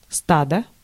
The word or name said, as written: drove